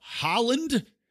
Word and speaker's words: Haaland: is you're seeing this Representative Deb Haaland, H-A-A-L-A-N-D, I think it's pronounced like the country, Haaland. Maybe it's Haaland. Maybe it's pronounced like halva.